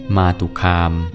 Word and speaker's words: มาตุคาม: สวัสดีครับพบกับรายการคำวัดวันนี้ขอเสนอคำว่ามาตุคามคำว่ามาตุคามสะกดด้วยมอม้าสระอาต่อเต่าสระอุคอควายสระอามอม้าอ่านว่ามาตุคาม